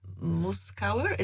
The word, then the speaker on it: Muskauer: Muskauer, is it how you say it in English? Muskauer Park. Murzakowski in Polish.